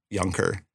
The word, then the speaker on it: Junker: It's spelled Junker, pronounced Junker.